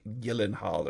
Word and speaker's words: Gyllenhaal: And he's like, yeah, it's Swedish. It's actually pronounced like Gyllenhaal or something.